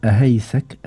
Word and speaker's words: أهيسك: في أبو راش أبو سعد بيقول لي كيفية نطقها، يعني بيقول أهيسك، هكذا تنطق يا أبا أحمد؟